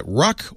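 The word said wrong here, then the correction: Ruch: Oregon, is that right? – Roush, Oregon.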